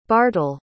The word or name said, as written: bartel